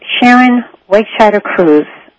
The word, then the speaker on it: Sharon Wegscheider-Cruse: All right, my guest today is Sharon Wegscheider-Cruse, and her book is Learning to Balance Your Life, 6 Powers That Restore Your Energy and Your Spirit.